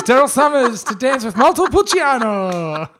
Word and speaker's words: Montepulciano: Montepulciano.